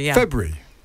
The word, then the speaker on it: February: February. – Oh, that sounds wrong. February. I don't know. Is it February? I can't say the R. – Isn't that a silent R?